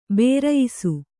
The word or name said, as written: ಭರಾಯಿಸು